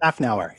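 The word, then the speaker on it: Szafnauer: And that is, I'm sure I said that right, Ottmar Schaffnauer. – Schaffnauer, exactly.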